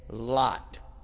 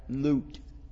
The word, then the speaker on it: Lut: They say Lot. They're mispronouncing it. They need another O and it would be correct. Lut, because that is his name in the Semitic language.